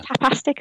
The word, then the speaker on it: Tapastic: is it Tapastic? Is that how you say it? – It is.